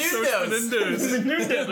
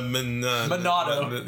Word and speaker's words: Monado: We always say it wrong. – Menundo art. Well, Chris says it wrong. – Oh, yeah. – No, we do. Because it's just funny. – The Menundo art. – We all know it's really called the Monado. – The Monado, yes.